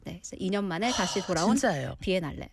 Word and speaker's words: Biennale: How do you pronounce it? – Biennale. – Biennale.